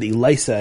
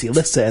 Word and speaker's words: Elissa: I said Elisa, and you said no, it's Alyssa, and there's a story behind it.